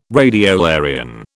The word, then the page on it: radiolarian: British English